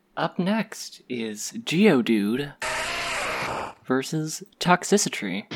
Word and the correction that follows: Toxtricity: Toxtricity. I always get that mispronounced.